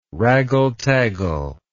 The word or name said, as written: raggle taggle